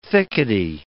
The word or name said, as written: thickety